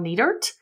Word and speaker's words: Nietert: I am your mental health coach, Michelle Nietert. And my name is spelled N-I-E-T as in Tom, E-R-T as in Tom.